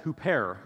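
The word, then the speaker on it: huper (ὑπέρ): There's this Greek word that's pronounced huper.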